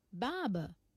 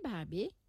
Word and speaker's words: Barbé: B-A-R-B-E-R. Saï. B-A-R-B-E-R. En français, ou pour lire, Barbé. En anglais, ou pour prononcer, Barbe. Et en créole, ou pour prononcer, Barbé. Sa style écrit, Barbé, est bien connu.